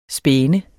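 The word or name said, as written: spæne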